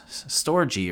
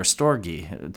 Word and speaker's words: storge: There is storge, which is familial love or the love that is found between family members, storge or storge, depending which way you pronounce it.